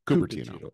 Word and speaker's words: Cupertino: Her name is Tabia Lee, and she was a DEI director at De Anza College in Cupertino, California. Did I say that correctly? – Cupertino.